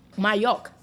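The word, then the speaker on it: Majok: And this show was written by Martina Majuk. Am I saying that properly? – Majuk. – Thank you, darling.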